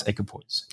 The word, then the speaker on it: equipoise: How do you pronounce equipoise? – You got it.